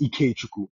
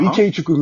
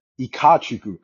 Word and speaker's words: Ikechukwu: Like he said, so my middle name is Ikechukwu, right? So Ikechukwu means strength of God. He pronounced it Ikachukwu.